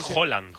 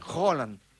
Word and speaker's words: Haaland: Y cuando vos te topás con uno más o menos fácil, la pronunciación correcta es totalmente distinta. – Como Haaland. Sí, flaco. – Por ejemplo. – Claro, Haaland... – Es Haaland. – Haaland. Como si dijeras un queso, ¿no? – ¿Sabes qué pasa el martes?